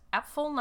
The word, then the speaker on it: Apfel: Yes, Apfel, not apple. I'm not just drunk and pronouncing it incorrectly. Apfel is German for apple.